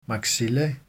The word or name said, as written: maxilla